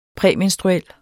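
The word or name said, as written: præmenstruel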